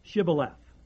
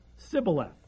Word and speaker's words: Shibboleth: Verse 6, then they said unto him, say now, Shibboleth. And he said, Sibboleth. For he could not frame to pronounce it right.